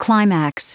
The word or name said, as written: climax